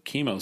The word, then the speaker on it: Chemo: Simon Stagg bursts in, reveals that Metamorpho 2 has built the chemo scraper. Chemo? Chemo. How are we pronouncing it? Chemo. – That? – Like chemical. Chemo. – Chemo. – We say chemotherapy.